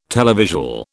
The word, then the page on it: televisual: British English